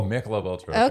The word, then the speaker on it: Michelob Ultra: A Michelob Ultra. – Correct. – Oh, Michelob Ultra.